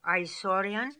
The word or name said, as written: Aisorian